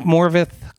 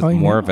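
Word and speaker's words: Morfydd: I think Morvith Clark, which I can't believe that's how you pronounce her name, but it is Morvith.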